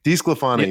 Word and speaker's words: DeSclafani: You mentioned is and I, I, can never say his name right. De, Desclafani is that Anthony Desclafani? – Desclafani.